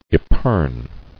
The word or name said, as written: Epergne